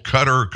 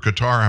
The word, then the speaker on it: Qatar: It's sitting in a bank account in Qatar, we're told, Qatar, Qatar, however you want to pronounce it. And by the way, they pronounce it both ways over there.